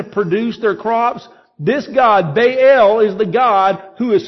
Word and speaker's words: Baal: Baal is the proper way to pronounce it.